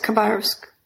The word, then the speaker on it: Khabarovsk: And you're from, forgive my pronunciation, Khabarovsk, right? In the far east of Russia? – Yes, Khabarovsk.